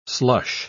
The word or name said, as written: slush